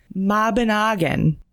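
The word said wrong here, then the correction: Mabinogion: No, Mobbinoggion. Oh my God, it's even more than that.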